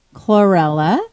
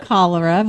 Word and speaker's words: chlorella: Recently we talked about chlorella, and I'm saying it correctly this time. – Very good job, Lisa. Nice. Very impressed. – Not cholera.